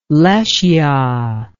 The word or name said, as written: Lesya